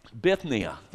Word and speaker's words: Bithynia: And Peter says, Peter, an apostle of Jesus Christ, to the pilgrims of the dispersions in Pontus and Galatia, Cappadocia, Asia, I practiced this 15 times before I left my office, and I said, that's going to get, it's just one of those things, I just can't say it right, okay? Bithynia, there we go.